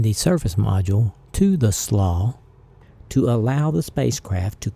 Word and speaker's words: SLA: abbreviated SLA and pronounced SLAW, come off.